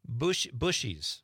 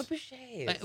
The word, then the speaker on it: Bouchers: There was a bakery in Marinette called... Bushies. – Bushies. – But it was spelled Bouchers. – Oh, Bouchers. – Which sounds French.